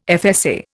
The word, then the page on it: FSA: Indian